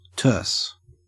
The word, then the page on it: terse: Australian English